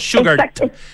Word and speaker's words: Sugarte: Absolutamente, hay demasiadas características que hacen de Sugarte, que se pronuncia en el fondo como si fuera sugar, como dulce, pero con una T al final, ¿cierto? Sugarte.